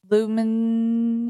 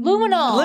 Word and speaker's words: luminol: He was telling police that, but they went into the bathroom and used that substance which is called lumin luminol.